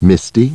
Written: Misty

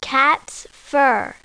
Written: cats fur

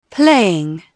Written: playing

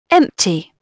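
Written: empty

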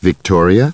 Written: Victoria

